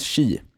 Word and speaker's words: Chia: nu hör jag från chatten här att det uttalas chi,